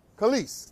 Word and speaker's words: Kelis: But then did you guys hear about Kellis? Right? Am I pronouncing her name right? Kellis? Yeah. Kelis. Thank you.